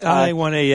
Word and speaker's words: Tianhe-1A: For example, China's got the how do you pronounce this? The Tianhe-1A. – Tianhe-1A, yeah.